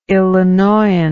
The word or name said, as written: illinoian